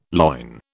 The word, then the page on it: loin: British English